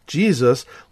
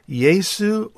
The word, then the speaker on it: Jesu: From our modern English rendition, Jesus, let's just go back one layer to 12th century early Middle English, and we have the form J-E-S-U, pronounced either Yesu or Jesu.